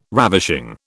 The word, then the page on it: ravishing: British English